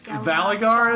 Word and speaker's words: Valagar: Like, Valagar is what you said his name? – Yep.